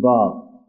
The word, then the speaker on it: با: ولی وقتی خواستیم تلفظ بکنیم نمیگیم جه، بگیم جیم. نمیگیم به، میگیم با.